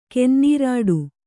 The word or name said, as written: ಕೆನ್ನೀರಾಡು